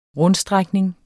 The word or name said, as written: rundstrækning